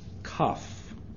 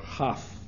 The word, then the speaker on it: Kaf: Kaf, or Chaf is the another way that is pronounced.